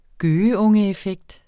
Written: gøgeungeeffekt

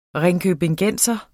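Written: ringkøbingenser

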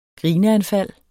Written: grineanfald

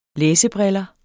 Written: læsebriller

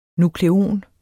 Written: nukleon

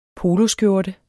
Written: poloskjorte

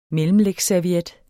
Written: mellemlægsserviet

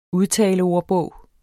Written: udtaleordbog

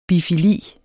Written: bifili

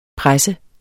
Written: presser

